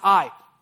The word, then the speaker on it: Ai: It's spelled Ai. You didn't realize how relevant the Bible was. They had Ai thousands of years ago in Israel. But it's actually pronounced Ai.